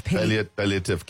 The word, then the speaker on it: palliative: Palliative care. – Palliative care.